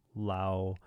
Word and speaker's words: Laos: For those Lao people out there that know the country, I shouldn't pronounce the S. I know I get it, but for other folks that see the S at the end, I'm going to pronounce it right. Lao, probably. It's probably better.